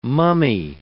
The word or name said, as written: mummy